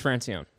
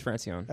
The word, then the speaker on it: Francione: It is Francione. – Francione. – It is Francione.